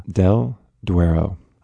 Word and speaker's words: del Duero: del Duero.